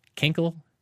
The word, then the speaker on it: Kinkle: Kinkle, kinkle. Now, I will pronounce it the way they would in Kentucky. This is a Kentucky. Kinkle, kinkle. – You got a pee? – Kinkle, kinkle? – Yeah, K-I-N-K-L-E.